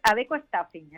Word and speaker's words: Adecco Staffing: Vamos a hablar con directora de servicio de Adecco Staffing. Creo que se pronuncia así. Buenas tardes. – Hola, buenas tardes. Nada, encantada de saludarte y muchas gracias por darme este espacio. Pues es Adecco Staffing, exactamente.